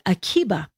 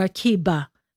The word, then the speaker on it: Akiva: It's spelled with a B or a V, Rabbi Akiva or Akiba. So it's a Vita, but it's the Greek B is pronounced with a V.